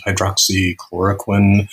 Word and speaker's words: hydroxychloroquine: There is, I mean, there's hydro hydro what is hydroxychloroquine uh, that that uh, is that the right am I saying that right? Yeah, that's that, right.